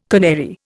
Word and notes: canary: Indian

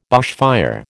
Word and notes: bushfire: American English

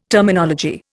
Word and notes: Terminology: Indian

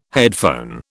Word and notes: headphone: British English

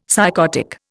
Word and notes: psychotic: Indian

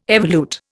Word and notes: evolute: Indian

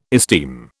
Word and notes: esteem: British English

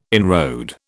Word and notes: inroad: British English

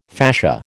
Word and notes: fascia: American English

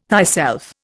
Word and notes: thyself: Indian

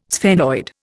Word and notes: sphenoid: Indian

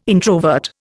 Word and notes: introvert: Indian

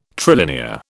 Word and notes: trilinear: British English

